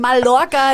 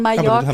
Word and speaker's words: Mallorca: Nein, der heißt einfach, man spricht die zwei L als J aus. So wie es auch nicht Mallorca heißt, sondern Mallorca.